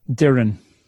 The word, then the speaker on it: Doireann: Doireann.